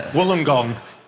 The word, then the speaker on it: Wollongong: In Australia, at their home near Wollongong, and she Wollongong? – Is that how it's pronounced? Is it like that? – From the reaction, I'm not sure that's how you do pronounce it. – Wollongong.